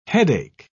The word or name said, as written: headache